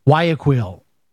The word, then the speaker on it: Guayaquil: I got to learn how to pronounce these cities, but it's Guayaquil, the major city.